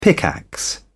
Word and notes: pickaxe: British English

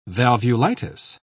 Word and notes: valvulitis: British English

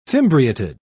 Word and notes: fimbriated: British English

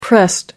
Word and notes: pressed: American English

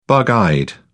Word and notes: bug eyed: British English

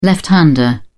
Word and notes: left-hander: British English

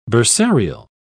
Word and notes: bursarial: British English

